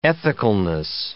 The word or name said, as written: ethicalness